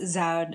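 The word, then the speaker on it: Zuyd: It's pronounced Zuid. It's Dutch, so it's really hard. So as the video said, there's actually four programs in North America, one's in Canada, and they're all really fantastic programs.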